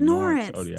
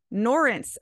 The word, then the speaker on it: Norense: But it's Norense, like Lawrence, but with an N. – Norense.